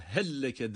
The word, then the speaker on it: هَلَّكَ: Burada helleke derken helleke. Heldeki lam cezimli, lekedeki lam üstün harekeli. Bu durumda cesimli olan lamı, harekeli olan lam harfine idgam ediyor. Katıyoruz yani, ona bağlıyoruz. O zaman şeddeli gibi okunuyor.